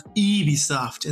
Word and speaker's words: Ubisoft: They kind of break the fourth wall a lot and kind of talk, and they're like, how do you even say Ubisoft? And then it's like, it's pronounced Ubisoft. And then he goes, ugh, the French.